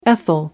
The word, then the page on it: ethyl: American English